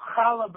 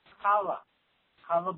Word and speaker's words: challah: You do not call challah bread challah bread. It's challah bread.